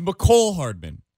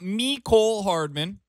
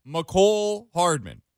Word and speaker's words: Mecole Hardman: It is Mecole Hardman. It is not Mecole Hardman, which is what some had been saying previously. It is Mecole Hardman. Mecole Hardman. That is how you pronounce his name.